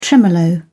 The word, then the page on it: tremolo: British English